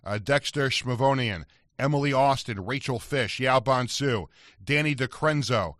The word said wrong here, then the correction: DiCrescenzo: did I pronounce that right? DiCrenzo, right? Josh, you'll help here? – It's DiCrescenzo.